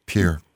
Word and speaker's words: Pierre: Pierre. – Spelled like Pierre, but Pierre.